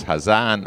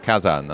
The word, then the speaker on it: Hazan: And if you've listened to the interview with him already, you'll know that he brought me up on the pronunciation of his name. It is Hazan and not Kazan.